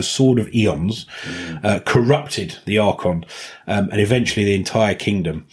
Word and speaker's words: Archon: A-R-C-H-O-N.